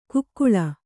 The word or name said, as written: ಕಾಕೋಳಿ